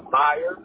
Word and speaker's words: Myers: Myers, M-Y-E-R-S. Got it. And Ricky, you spell that R-I-C-K-Y?